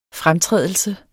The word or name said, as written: fremtrædelse